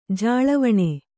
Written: ಜಾಳವಣೆ

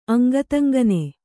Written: ಅಂಗತಂಗನೆ

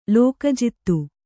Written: ಲೋಕಜಿತ್ತು

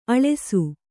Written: ಆಳಸಿ

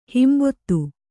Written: ಹಿಂಬೊತ್ತು

